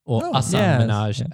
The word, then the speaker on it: Minhaj: And Patriot Act with Hasan Minhaj, Minhaj, as it's meant to be pronounced, as so I'm told, which is sort of Netflix's newest attempt at a political talk comedy show.